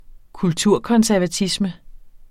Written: kulturkonservatisme

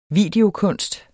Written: videokunst

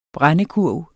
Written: brændekurv

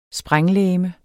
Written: sprænglegeme

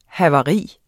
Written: havari

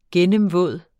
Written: gennemvåd

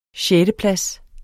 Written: sjetteplads

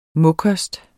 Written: mokost

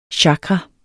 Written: chakra

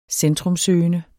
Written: centrumsøgende

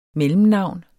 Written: MELLEMNAVNET

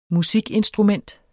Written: MUSIKINSTRUMENTET

